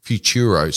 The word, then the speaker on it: Futuro: So the website address is Futura Avenue. That's Futuro, I mispronounced it. Don't listen to me at all. Futuro, F-U-T-U-R-O, Avenue, as you'd expect Avenue to be spelt, but it's all one word.